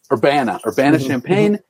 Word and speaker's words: Urbana-Champaign: Urbana-Champaign.